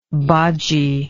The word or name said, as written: bodgie